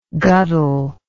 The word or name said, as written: guddle